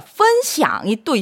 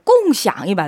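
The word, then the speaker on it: 꽁샹 (共享): 꽁이 사성이 아닌가요? 했는데요. 네, 꽁 여러분 사성 맞습니다. 네. 네, 제가 여기 필기를 일성으로 해놨는데요. 그 이유가 공유하다가 펀샹이 또 있어요. 그래서 지금 꽁샹이라고 발음했는데 꽁샹이 맞아요, 여러분. 펀샹, 꽁샹 두 가지 다 됩니다, 여러분.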